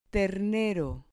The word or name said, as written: ternero